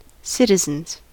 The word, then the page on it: citizens: American English